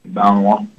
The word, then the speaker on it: Malinois: Malinois.